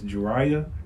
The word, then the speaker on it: Jurayah: It's Juraya, Juraya, just J U R A Y A H, to get tested in the city over.